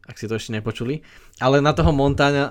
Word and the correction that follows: Montaigne: e, ak neviem, ako sa to vyslovuje, na toho... – Montaigne.